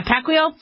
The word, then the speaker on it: Pacquiao: I hope I'm not pronouncing that. Pacquiao? Yeah, Pacquiao.